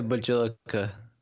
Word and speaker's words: Bjelica: It's definitely a Bjelica. – It's silent. The B is silent.